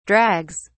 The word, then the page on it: drags: British English